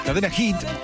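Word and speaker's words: Cadena Heat: Cadena Hit, se escribe Heat.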